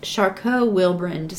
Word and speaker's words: Charcot-Wilbrand: I could completely butcher it, but it looks like it's Charcot-Wilbrand syndrome.